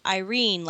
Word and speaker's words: Irene: Irene, like somebody's grandmother's name.